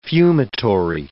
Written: fumitory

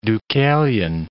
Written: Deucalion

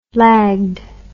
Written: lagged

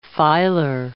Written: filer

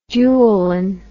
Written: dualin

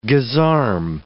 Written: gisarme

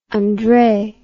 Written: Andrei

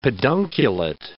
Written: pedunculate

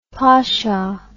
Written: posho

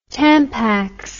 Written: Tampax

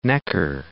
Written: Neckar

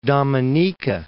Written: Dominica

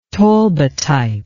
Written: talbotype

